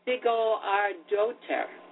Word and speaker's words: Sigurðardóttir: Jóhanna Sigurðardóttir. I guess. – Oh, that was good of you to say that. I couldn't imagine how to pronounce that. – Sigurðardóttir.